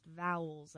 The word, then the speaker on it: AEIOU: That's how it's spelled, but it's technically pronounced vowels, I recently learned.